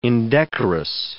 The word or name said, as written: indecorous